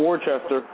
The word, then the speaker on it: Worcester: Yeah, it's not Worcester there, Chris. It's Worcester. Not Worcester. Worcester. – And what town, what city are we talking about? – Massachusetts. – Oh, so Worcester, Mass.